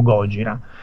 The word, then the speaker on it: Gojira: E, e, quindi fondamentalmente da un nome che avevano creato da, in inglese Godzilla dice no questa cosa non si può pronunciare nella, nella televisione giapponese lo chiamiamo Gojira e